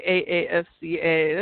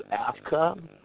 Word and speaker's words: AAFCA: AAFCA. That's awesome. – Yeah, we pronounce it AFCA, but AAFCA fine too.